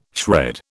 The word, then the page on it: shred: British English